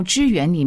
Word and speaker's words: 支援: 请问一下，在这个教牧支援里面我们要提供什么样的资源呢？ – 我们这个发音很重要啊，这叫做教牧支援。